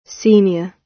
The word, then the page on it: senior: British English